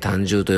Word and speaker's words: 胆汁: 胆汁って聞いてですね、すぐにピンとくる方もいるかもしれませんが、胆汁とはですね、肝ですね、胆という字に汁と書いて、まあ、胆汁と書いて胆汁と読むのですが、これはですね、過度のお酒や、疲労ですね、あとストレスで胆汁などの分泌が、悪くなってしまう方が非常に多いんですね。